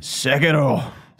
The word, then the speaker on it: Sekiro: Enjoy Sekiro. – That was pretty good pronunciation. – Sekiro.